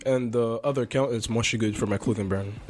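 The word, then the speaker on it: Moi Chuis Good: Oh yeah, Yo, so Yo Dollar, so Y-O space D-O-L-L-A, and Mushy Good is M-O-I space C-H-U-I-S space G-O-O-D for Mushy Good.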